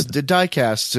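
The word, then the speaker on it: dikast: Got a word I can't pronounce there. It's spelled D-I-K-A-S-T-S. – It's dicast.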